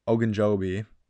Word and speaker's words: Ogunjobi: Cedric Ogujo, I cannot pronounce the name right now. I'm so sorry. Cedric O, the tackle, Ogunjobi, there we go, is out.